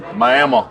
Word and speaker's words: Miami: Do you know how Bobby Bowden would pronounce Miami? – Miami. – That's right, just like that.